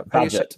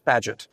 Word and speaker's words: Bagehot: It's Walter Bagehot, is that how Bagehot? – Bagehot.